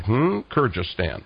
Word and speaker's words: Kyrgyzstan: Kyrgyzstan. I actually said that right. Kyrgyzstan.